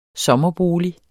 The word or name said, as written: SOMMERBOLIG